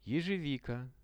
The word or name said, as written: ezhevika